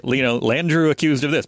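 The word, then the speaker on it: Landrieu: Landru. It's L-A-N-D-R-I-E-U. Landru. – Landru. They pronounce it real weird.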